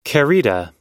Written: querida

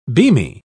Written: beamy

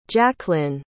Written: jaclyn

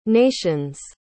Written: nation's

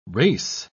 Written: rase

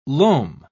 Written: loam

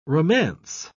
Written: romance

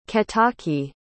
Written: ketaki

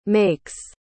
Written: makes

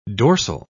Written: dorsal

